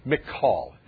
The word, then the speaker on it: Michal: Remember King David in Old Testament was married to a woman named Michal. Her name actually is pronounced Michal, if you will. And it looks like Michael.